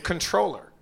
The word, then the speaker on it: comptroller: It's apparently controller. You say it like control. – You don't pronounce the M and the P? – That's what I've been led to believe.